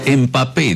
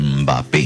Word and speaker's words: Mbappé: No, porque le agrega una E adelante, empapé, y no, yo lo vi, MB, pero se pronuncia Mbappé, Mbappé. Juntando los labios, Peter, Mbappé, así, Mbappé.